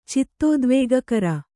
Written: ಚಿತ್ತೋದ್ವೇಗಕರ

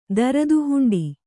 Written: ದರದುಹುಂಡಿ

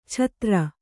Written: ಛತ್ರ

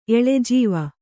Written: ಎಳೆಜೀವ

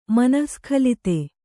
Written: ಮನಃಸ್ಖಲಿತ

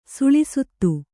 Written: ಸುಳಿಸುತ್ತು

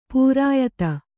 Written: ಪಿರಿಯಾದಿ

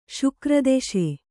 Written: ಶುಕ್ರದೆಶೆ

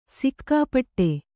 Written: ಸಿಕ್ಕಾಪಟ್ಟೆ

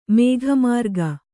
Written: ಮೇಘಮಾರ್ಗ

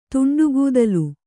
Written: ತುಂಡುಗೂದಲು

